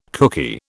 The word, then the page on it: kooky: British English